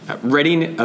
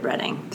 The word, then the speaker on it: Reading: Reading appears on the Monopoly board, though it is often mispronounced as the Reading Railroad, but we are the Reading Railroad. So now when you play Monopoly, I hope you will pronounce the railroad correctly, please.